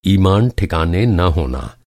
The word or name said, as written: ईमान